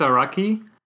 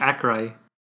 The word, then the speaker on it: Araki: Because I think it's Araki. – Right. – But I keep saying Akrai. – Hey, the mangaka? – Yeah. – Oh, really? – The A-R-A-K-I. – A-R-A-K-I.